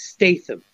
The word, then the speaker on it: Statham: I actually thought, you pronounce the T. It's Statham, you son of whatever.